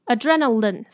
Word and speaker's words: adrenaline: 好, 我们讲完连音的部分了, 接下来我们来讲单字, 这句话有两个单字, 非常的容易让人家觉得, confused的感觉, deadline 跟 adrenaline, 后面四个字母都是L-I-N-E, line, 但是两个字发音不一样哦, 所以要注意, deadline, deadline, 是line, adrenaline, 是lin, n, lin, adrenaline, deadline, 好, 大家可以念念看, 再来, 我知道大家在想什么, 这句话最后一个字要怎么念嘞?